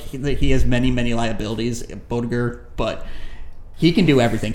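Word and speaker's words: Boettger: And how do you pronounce it? Eek Boger? Is that how you pronounce it? – Bodiger. – Yeah, Bodiger.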